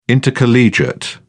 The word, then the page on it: intercollegiate: British English